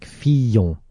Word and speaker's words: Fillon: Says, please tell your newsreader to pronounce Fillon with long Y, like Fillon.